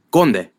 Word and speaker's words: Conde: People see my last name, and I the way I pronounce it, and for everyone to say it, it's Conde.